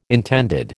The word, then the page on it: intended: American English